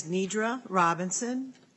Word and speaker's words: Nedra Robinson: For the record, my name is Nedra Robinson. You spell it N-E-D-R-A-R-O-B-I-N-S-O-N.